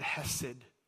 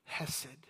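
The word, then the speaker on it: hesed (חסד): It is the word hesed. Hesed. It's spelled chesed, but it's pronounced hesed.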